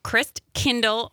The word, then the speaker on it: Christkindl: So tell people about Kris Kringle's Village. – Christkindl Market on Broadway. – Did I mispronounce? – Yes, yep, you did.